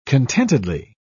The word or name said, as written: contentedly